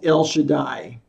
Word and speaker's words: El Shaddai: In Hebrew, the Almighty God is pronounced El Shaddai.